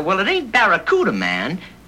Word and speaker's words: Barracuda: Hey, man, the name of the Plymouth Fastback is the Barracuda. – I can't pronounce Barracuda. – Yeah, well, look, man, try this. Say ba. – Ba. – Ra. – Ra. – Coo. – Coo. – Da.